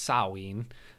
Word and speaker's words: Samhain: So that's the the interesting thing is I got into uh, Samhain, or as it is correctly pronounced, Samhain.